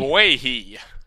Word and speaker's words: Ogbuehi: Ogbuehi.